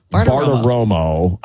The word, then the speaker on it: Bartiromo: Bartiromo.